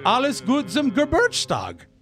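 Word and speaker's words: Alles Gute zum Geburtstag: Happy Alice Gutzum Geburtstag. – Yeah, that's German for happy birthday. – Well, how do you pronounce it? – Alles gut zum Geburtstag.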